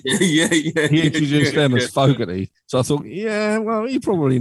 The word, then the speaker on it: Fogerty: And I think they all said it's Fogerty, but is it like, well, they don't pronounce the T either, probably. They pronounce it as a D, probably, don't they?